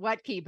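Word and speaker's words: Wuttke: It's not, you taught me how to pronounce it, Wettke, but that's not how you spell it.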